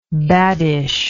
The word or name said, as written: baddish